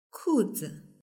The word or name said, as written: kuzi